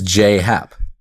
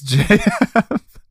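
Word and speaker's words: J.A. Happ: he pronounces his name as J-A-Y, so J. – So it is J-Hap. – Yeah, it's J-Hap.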